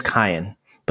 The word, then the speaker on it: Kayan: So, just wanted you to know, before all the messages start coming in, his name is Kyan, but no two people seem to pronounce it the same, and we're all okay with that.